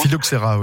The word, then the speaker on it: Phylloxera: le Philostrea, mhm. je le prononce mal, excusez-moi. – Phylloxera, oui, c'est pas grave.